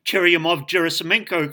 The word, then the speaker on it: Churyumov-Gerasimenko: You may ask why I've gone to the trouble of pronouncing Comet 67P Churyumov-Gerasimenko correctly.